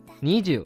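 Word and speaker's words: にじゅう: 二的读法是你，十的读法是 ju，合起来就是你 ju，这就是二十的正确读法。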